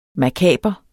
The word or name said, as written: makaber